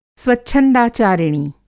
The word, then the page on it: स्वच्छंदाचारिणी: Hindi